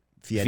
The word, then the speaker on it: Fieri: Fieri.